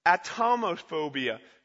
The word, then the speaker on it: Atomophobia: Atmosophobia. Sorry, I pronounced it wrong. Atomophobia.